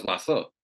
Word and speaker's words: WASA: And, you know, we have institutions in Trinidad that have acronyms, and some of the acronyms, you say the letters and some of them I pronounce it. For example, WASA. – Mm-hmm. – You know, that's WASA. – Mm-hmm. – TST, you know, that's the TIST, right?